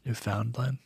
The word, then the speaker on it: Newfoundland: he's from Newfoundland. Mm. I'm saying that wrong, I'm sure. – Newfoundland.